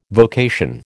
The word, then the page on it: vocation: American English